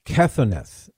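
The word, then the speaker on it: kethoneth: Ketheneth is the way you would pronounce it.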